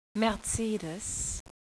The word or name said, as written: Mercedes